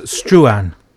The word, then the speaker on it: Struan: And on Wednesday, we've got, I'm not sure how you say his name. Do you? – Yes, Struan.